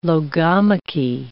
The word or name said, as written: logomachy